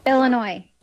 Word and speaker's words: Illinois: Él, español, ella estadounidense, concretamente de Illinois, y fue quien grabó este audio para que escuchemos cómo se dice realmente. – Aquí te doy la vuestra. – Illinois.